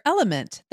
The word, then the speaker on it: LMNT: Sarah, we're welcoming our sponsor, Element. That's spelled L-M-N-T,